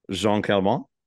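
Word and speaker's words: Jeanne Calment: You told the extraordinary story of Jean Calment.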